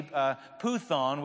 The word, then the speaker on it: Python: In Greek mythology, puthon, which is spelled python, right?